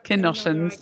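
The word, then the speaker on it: Canossian: I'm glad you said that, because I couldn't work out how to pronounce that. I'm thinking Kanoshans. – Yeah, the Kanoshan Daughters of Charity, okay, to be precise.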